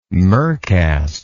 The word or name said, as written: mercast